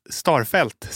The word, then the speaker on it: Starfelt: De nominerade är Isak Hien, Hellas Verona, Victor Nilsson Lindelöf, Manchester United och Karl Starf Starfelt, Celta Vigo hade problem med uttalet på starten.